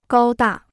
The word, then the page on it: 高大: gāo dà